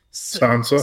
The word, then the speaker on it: Sansa: Sansa. – Sansa, yeah. Sansa's down there. I love the line. – And Melisandre. – We get a little like humor break when Sansa says that Tyrion was the best of her husbands, and he says, "Oh, what did he say?